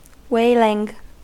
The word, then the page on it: wailing: American English